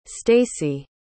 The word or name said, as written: stacie